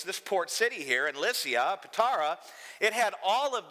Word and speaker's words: Lycia: Lycia is spelled L-Y-C-I-A, but it's pronounced Lycia. It's just kind of a weird Greek thing.